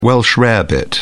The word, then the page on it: Welsh rarebit: British English